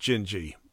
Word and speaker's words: Dindi: Well, I don't want to just go on and on about it, but the name, it's pronounced Dindi. I had to look that up. But it's spelled D-I-N-D-I, and it's a Brazilian name.